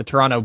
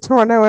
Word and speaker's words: Toronto: I also like that Toronto only has one T in it. It starts with it. Toronto. – What? – No one pronounces the second T in Toronto.